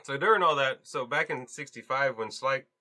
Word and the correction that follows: Schleich: How do you say it again, Michael? – Schleich. – Kellick? Schleich? Schleich.